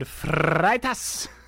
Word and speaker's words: Freitas: I believe it is David Freitas. – Indeed. If we look at David Freitas. – It's kind of a German-Portuguese pronunciation for those of you keeping score at home.